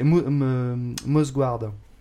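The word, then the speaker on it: Mouse Guard: Ouais, donc les Légendes de la Garde, c'est Mosguard. Ça va, je l'ai bien prononcé, tu vas pas me faire chier. – Non, très très bien.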